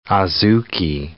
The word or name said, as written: adzuki